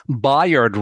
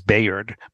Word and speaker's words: Bayard: And this is a movie about Bayard Rustin, who, first of all, I'm glad I saw this trailer because I never knew the correct pronunciation. I always thought it was Bayard, but no, it's Bayard Rustin,